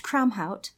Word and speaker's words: Kramhout: Okay, so it's pronounced Kramhout. And what I tell people... I told a coworker of mine, Kote, that I did a podcast with him, that it's very easy. It's pronounced Krom, like Conan's god, and then Hout, like house.